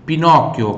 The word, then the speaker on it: Pinocchio: Pinocchio, mais uma vez o CHI aqui com som de chi.